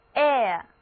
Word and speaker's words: heir: H-E-I-R, here 的意思就是继承人。注意字母 H 在这里不发音，整个单词读作 air, air。